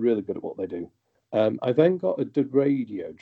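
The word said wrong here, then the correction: Deredeo: dreadnought or a Deradeo dreadnought to pronounce it.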